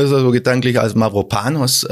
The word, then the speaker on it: Mavropanos: Spricht man tatsächlich Mavropanos? – Mavropanos haben ja meine griechischen Freunde.